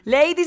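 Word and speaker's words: Leidy: Lady se escribe L-E-I latina D-Y. L-E-I latina D-Y. L-E-I latina D-Y, carajo, así se escribe lady.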